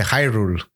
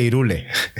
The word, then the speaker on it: Hyrule: que no se dice Hyrule, que se dice Irule. – Ni de coña, ni de coña.